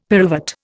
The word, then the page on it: pyruvate: Indian